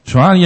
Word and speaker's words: Zwani: Zwani, zeg ik het goed? Ja. Zwani.